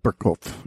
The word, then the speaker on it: Burgkopf: We're doing a Burgkopf, if I said that correctly. – Yep. Burgkopf.